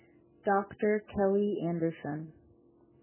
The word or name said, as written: Dr. Kelly Anderson